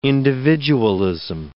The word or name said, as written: individualism